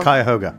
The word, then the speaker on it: Cuyahoga: Cuyahoga.